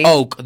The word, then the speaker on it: Oak: Lo digo así para, perdón la pronunciación, sí. Oak debería ser.